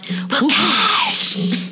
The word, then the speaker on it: Wukasch: And WooCash is WUKASCH. – And it's pronounced WooCash.